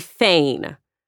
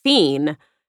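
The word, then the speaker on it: feign: One of them would be fein, F-E-I-G-N. I always pronounced it fiend, and I learned I was wrong, even though I know rain.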